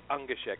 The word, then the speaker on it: Ungeschick: How do I pronounce your last name? – No, that's quite all right. I sometimes even forget how to pronounce my own name. and it's not great because it actually phonetically means clumsy in German, but it's Ungeschick.